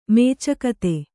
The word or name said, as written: ಮೇಚಕತೆ